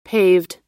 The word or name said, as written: paved